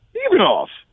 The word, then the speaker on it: Ivanov: How do you pronounce his name again? – Blagoy Ivanov. – Ivanov. – Yeah. – Yes, exactly. – Is saying it right? – Yeah.